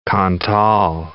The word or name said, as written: Cantal